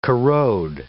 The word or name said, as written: corrode